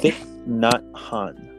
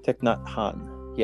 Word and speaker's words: Thich Nhat Hanh: Thich Nhat Hanh. Thich Nhat Hanh.